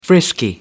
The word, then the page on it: frisky: American English